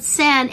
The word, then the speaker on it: Senn: But it's spelled Sen, S-E-N-N, like we were saying, like the Sennheiser